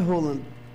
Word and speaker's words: Haaland: É Haaland a pronúncia? – A pronúncia correta é Haaland.